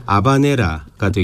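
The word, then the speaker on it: habanera: 이 스페인식 원어 발음으로는 H가 무음이니까 아바네라가 되겠습니다만 그렇죠.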